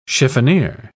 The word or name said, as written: chiffonier